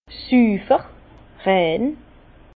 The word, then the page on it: süfer, rein: Bas Rhin; Herrlisheim